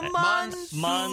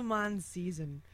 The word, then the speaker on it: monsoon: The monsoon season. – Oh, shoot. I didn't even say it right. I said the mon season.